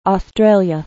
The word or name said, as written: australia